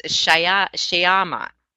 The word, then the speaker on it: Shyama: And her business card says rhymes with llama. Oh, it's Shyama.